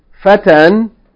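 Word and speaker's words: فتًى: If you take off the alif lam and put tanween on it, fatan, wahina idhin tuqadr al-alamat. In this case, the alif, yani, may not be pronounced, fatan, if it takes tanween.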